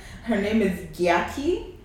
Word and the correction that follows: Gyakie: and the song is called Forever. – Wait. – Or is it Gyaki? – I think it might be Jackie. – Oh, it's spelled... G-Y-A-K-I-E. – Jackie. Okay. Her actual name is Jackie, but like...